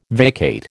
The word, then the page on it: vacate: American English